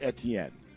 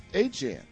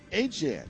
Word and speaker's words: Etienne: I'm going to try like hell to call new Saints running back this year Travis Etienne, because he says it's not pronounced Etienne. He says it's pronounced Etienne. Okay, then let's pronounce it Etienne.